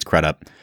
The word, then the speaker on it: Crudup: And I'm pretty sure that's how he pronounces his last name. I've heard Crudup, I've heard Crudup, but I think it's Crudup.